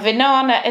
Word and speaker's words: Vinana: And Vinana is spelled V I N A N A. And yes, so many people tell me that Vinana rhymes with banana and they just remember it by banana.